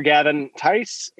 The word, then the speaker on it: Gavin Tice: Just look for Gavin Tice.